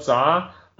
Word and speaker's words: Zha: And you can Google my name, Carl Zha. That's spelled with a C, C-A-R-L, last name Z-H-A, Zebra Henry Apple.